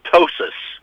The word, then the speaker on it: ptosis: But there's different things that can be done for this droopiness, which is called ptosis. Another one of those words like pterodactyl and psychiatrist that start with a P that you don't pronounce, P-T-O-S-I-S.